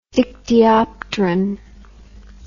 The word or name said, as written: dictyopteran